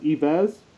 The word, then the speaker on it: Yves: I believe it's pronounced Yves. Y-V-E-S. Does that sound right?